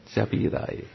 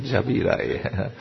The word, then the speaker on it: Žabi raj: How do you say this? Žabi raj. Jabirai, yes, Jabirai. You know, it was in a pen.